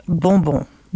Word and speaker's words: bonbon: It's pronounced bonbon. – Bonbon. – Bonbon in French means candy.